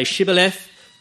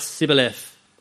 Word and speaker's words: Shibboleth: And the Gileadites could say Shibboleth, but the Ephraimites could only pronounce Sibboleth.